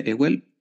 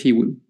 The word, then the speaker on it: Ewell: ¿se pronuncia Ewell? Bueno, creo que es Ewell.